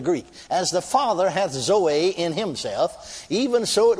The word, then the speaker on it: zoe: Now, then, this particular one here is spelled Z O E. Some call it Zoe, some pronounce it Zoe. I pronounce it Zoe.